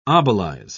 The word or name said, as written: obelize